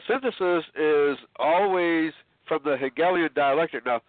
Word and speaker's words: Hegelian: Hegelian, yes. – I can tell you're not German. George Wilhelm Friedrich Hegel. – Yeah, the Hegelian, yes. – Right, there you go. It's two syllables, not one.